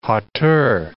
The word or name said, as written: hauteur